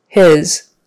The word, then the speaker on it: his: His. Short I sound, his. And of course, the S is pronounced as a Z, his. That is his, not hers, his. Food. So notice it's spelled F-O-O-D.